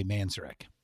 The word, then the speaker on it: Manzarek: That's how you say his name, by the way. – Manzarek. – Manzarek, yeah. – Okay. – Yeah, or Manzarek, I guess some people, but I was always taught it was Manzarek.